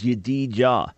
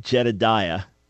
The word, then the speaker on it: Yedidiah: For example, Solomon's name was Yedidiah. He said, never heard of that. Well, Jedidiah in our English pronunciation. But Yedidiah, which means, beloved of Yahweh, loved by Yahweh.